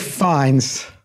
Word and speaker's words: Fiennes: You both mispronounced it. It's okay. Ralph Fiennes. – Fiennes.